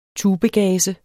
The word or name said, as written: tubegaze